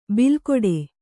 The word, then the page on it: ಬಿಲ್ಕೊಡೆ: bilkoḍe